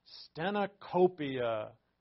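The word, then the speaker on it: stenocopia (στενοχωρία): Sounds like cornucopia. And that last O is an omega, so it has that long O sound. Stenocopia.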